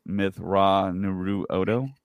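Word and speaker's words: Mitth'raw'nuruodo: So I was telling Zach earlier, if you just kind of break it down, break it up, I think it's pronounced Mithra-Nuru-Odo.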